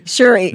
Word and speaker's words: Shuri: Shuri.